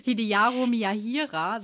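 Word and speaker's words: Hideharu Miyahira: Hideyaro Miyahira seit 2018. Yay! Richtig ausgesprochen.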